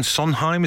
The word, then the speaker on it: Sondheim: Just before all the madness went on, you were in the Stephen Sondheim, is that how you say his name? – Yeah.